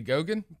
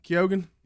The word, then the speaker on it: Keoghan: Barry Keoghan? – Barry Gogan? – Keoghan. – Yogan? – Irish Youngin. – Oh. – Yeah. Keoghan. – Yeah, give some American actors a swipe at it. – You say his name exactly how a redneck would pronounce it. Keoghan. – Keoghan. – It reads as Coogan.